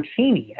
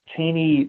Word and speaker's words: Cheney: And Strook thought he saw something of interest in young Cheney, or Cheney, actually, as they pronounced it back then. It was Cheney in later years, but Cheney at the time.